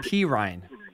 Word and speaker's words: Perine: Perrine, I don't know how to pronounce the backup from Cincinnati. – Samaje Perine. – Perine, thank you, is probably going to start the season as the starting running back.